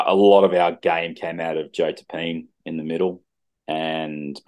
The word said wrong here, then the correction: Tapine: Can we say his name correctly? – Tapanay. – It's Tapanay. – Sorry. – Don't call him Tapine. I don't think he likes it.